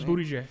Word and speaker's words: Buttigieg: It's Booty J.